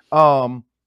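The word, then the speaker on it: Skowronek: Like, look, nobody's going to go with Skoneck or whatever, how you say his name. Skoronek. There it goes.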